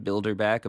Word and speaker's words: Bilderbach: I'll get a lot of like extra U's in my last name, Bilderbach. Of course, people want to just spell that like the thing that's an actual word. – Right. – But my favorite is when I get like mail addressed to Bilderbeck.